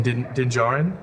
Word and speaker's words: Din Djarin: So, if I had to say who's the people champ right now, it's between you and Din Djarin.